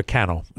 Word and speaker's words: Cannell: A guy named Stephen Cannell, or Cannell, who is the way to pronounce it.